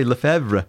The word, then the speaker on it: Lefebvre: Well, how do you pronounce it? – I say Lefebvre. – Well, you, that's right.